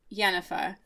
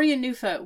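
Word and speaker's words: Jenůfa: We're really on the fence as to how this is actually pronounced. – I think it's Jenufa. – So when we... – In Czech. – When we saw it, that was the way that people sang it. However, I know in the Czech language that usually the first syllable is the one that's emphasized. However, it is someone's proper Christian name, so those rules don't actually matter.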